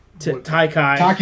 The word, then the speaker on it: Taika: That and Tiki. – Taika Waititi.